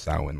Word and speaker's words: Samhain: Yeah, well, it's I think from what I've heard, it's supposed to be pronounced Samhain, like S-O.